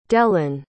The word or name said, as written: delane